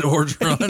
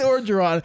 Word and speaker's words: Orgeron: Ed Orgeron. – Yeah, Ed Orgeron.